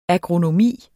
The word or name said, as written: agronomi